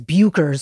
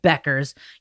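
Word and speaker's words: Bueckers: A lot of people think her name is Bukers because of the way it's spelled. It's Paige Beckers.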